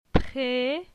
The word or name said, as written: Pre